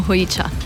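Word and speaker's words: hojicha: V bistvu Japonci izgovorijo hojiča, pri nas pa je v bistvu se lepše čuje, da tudi ljudje razumejo in uporabljamo hojiča.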